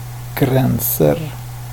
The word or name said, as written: gränser